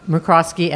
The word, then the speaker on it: McCroskey: Today will be a handmade episode featuring an incredible company right here, McCroskey Mattresses. Am I saying it right? – You are, thank you. – Introduce yourself. – My name is Robin McCroskey Azevedo, third generation of McCroskey Mattress Company owners.